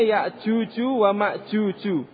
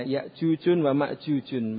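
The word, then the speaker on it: ya'juju wa ma'juju (يأجوج ومأجوج): Kalau dia asing maka dia mamnu minal sharf tidak ditanwin, bacanya ya'juju wa ma'juju. Iya. Ya'juju wa ma'juju. Aw Arabian atau dua nama Arab. Kalau Arab berarti dia boleh ditanwin, iya, ya'jujun wa ma'jujun bacanya. Tapi kalau dalam ayat-ayat kita baca seperti hatta idza futihat ya'juju wa ma'juj, tidak ditanwin, ya.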